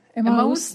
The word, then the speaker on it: Эммаус: В Эммаус, как правильно ударение? Знатоки, Эммаус или Эммаус, кто знает? – Эммаус, да, Эммаус. Да, вот они шли из Иерусалима и Эммаус.